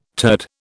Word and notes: turd: British English

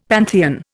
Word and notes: pantheon: Indian

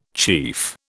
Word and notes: chief: British English

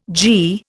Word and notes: gie: Indian

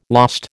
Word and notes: lost: American English

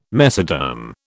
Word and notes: mesoderm: British English

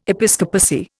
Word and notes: episcopacy: Indian

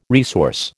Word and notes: resource: American English

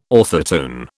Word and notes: orthotone: British English

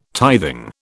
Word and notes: tithing: British English

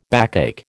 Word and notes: backache: American English

